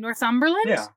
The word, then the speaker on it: Northumberland: He's from, I'm going to butcher this name, Northumberland? – Yeah.